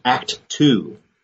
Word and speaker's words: ACTU: and they pronounce it Act Two, meaning it's not enough to just have faith and go to church.